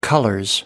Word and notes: colors: British English

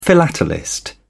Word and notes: philatelist: British English

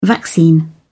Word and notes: vaccine: British English

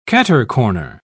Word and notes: catercorner: British English

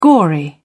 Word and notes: gory: British English

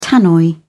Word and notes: tannoy: British English

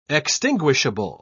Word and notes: extinguishable: British English

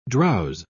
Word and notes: drowse: British English

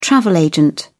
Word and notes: travel agent: British English

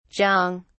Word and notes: jong: British English

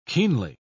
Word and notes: keenly: British English

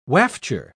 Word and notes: wafture: British English

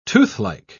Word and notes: toothlike: British English

